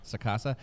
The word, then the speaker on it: Sacasa: Sacasa.